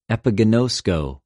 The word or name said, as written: ἐπιγινωσκω